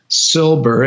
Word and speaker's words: Silber: type my name in to Amazon, but make sure you spell it, M-A-R-C, Silber, S-I-L-B, like boy, E-R.